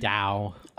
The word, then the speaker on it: Tao: It's Dao. – Oh, Dao. – No, there's there's either. They're the same thing, but they've been pronounced either way.